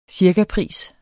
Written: cirkapris

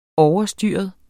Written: OVERSTYREDE

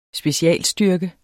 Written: specialstyrke

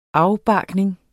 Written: afbarkning

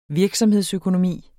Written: virksomhedsøkonomi

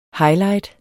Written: highlight

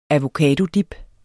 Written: avocadodip

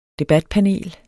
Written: debatpanel

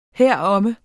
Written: heromme

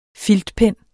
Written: FILTPENNE

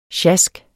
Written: sjask

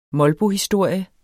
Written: molbohistorie

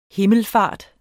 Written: himmelfart

